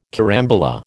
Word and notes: carambola: American English

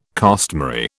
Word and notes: costmary: British English

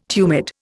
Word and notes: tumid: Indian

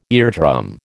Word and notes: eardrum: American English

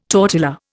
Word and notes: tortilla: Indian

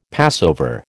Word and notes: Passover: American English

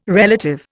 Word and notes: relative: Indian

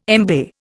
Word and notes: embay: Indian

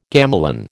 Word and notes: gamelan: American English

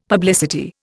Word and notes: publicity: Indian

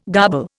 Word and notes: garble: Indian

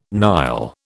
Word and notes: Nile: British English

